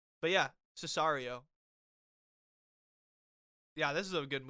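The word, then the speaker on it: Sicario: I can't remember how to pronounce it. Sicard? It's like that war. It's like that war film. I'll pull it up for you so you can see it. I think that's how you pronounce it. – Oh, Cesario. – Is it Cesario? Oh. Oh, I was reading the O as a D.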